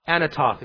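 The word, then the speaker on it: Anathoth: But he lived in a small town near Jerusalem, but he wasn't involved, Anatoth, excuse me.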